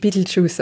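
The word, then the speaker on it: Betelgeuse: Name in Wirklichkeit, aber auf Englisch spricht man ihn tatsächlich Beetlejuice aus.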